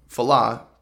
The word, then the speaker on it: Fallah: Adil El Arbi and Bilal Falal, Fallah, pardon my pronunciation,